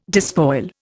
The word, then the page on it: despoil: Indian